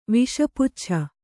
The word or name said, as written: ವಿಷಪುಚ್ಛ